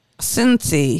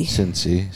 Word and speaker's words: Cincy: That's at Cinky. What's up, Cinky? – Cincy. – Cincy, sorry. Shenzhen, China.